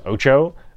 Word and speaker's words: OTXO: Last thing, last but not least, number 7, I guess this is supposedly pronounced Ocho, O-T-X-O, official release trailer.